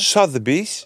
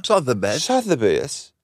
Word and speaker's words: Sotheby's: Hoe spreek je dat nou uit? – Sotheby's. – Oh, ja, ja. – Sotheby's.